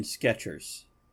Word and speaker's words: Skechers: There's no T in Skechers. It's more like Skechers, but only one E in the first syllable.